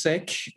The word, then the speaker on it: Sikh: Technically it's Sikh. It's spelled S-I-K-H. So I'm a Sikh American. I know a lot of people say Sikh because there's confusion on what people are saying when they say Sikh. But yes, technically it is S-I-K-H and pronounced Sikh.